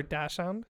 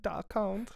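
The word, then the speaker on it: Dachshund: Is it dash or dack? I don't know. – I guess... – What? Dak or dash hound? – Depends. Some people pronounce it Dak, and some people pronounce it dash.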